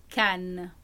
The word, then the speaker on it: Cannes: Se reúnem em uma cidade no sudeste da França, Cannes la ville de Cannes, on prononce como ça en français.